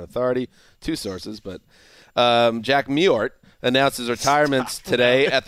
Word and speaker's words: Mewhort: I think it's Mewhort. – No. That's in Mewhort. – No. Mewhort. The W is basically silent.